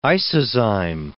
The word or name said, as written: isozyme